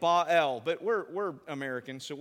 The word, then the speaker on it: Baal: Baal was an interesting god, and, and it's actually pronounced uh, Baal, if it's kind of like Ja. Uh, uh, what's Superman's dad's name? Eh? Yeah, it's kind of Jor-El, Baal.